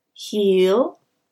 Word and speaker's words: heel: we say hill, hill. So the E sound is much shorter. Hill, hill, heel, hill, heel, hill.